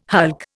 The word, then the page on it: hulk: Indian